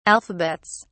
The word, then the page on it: alphabets: British English